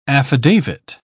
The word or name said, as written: affidavit